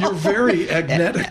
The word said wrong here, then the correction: agentic: Yeah, exactly. I'm getting there. Agentic. Yeah. All right. – And also, remember how you say it? Agentic? – Agentic AI. – Agentic.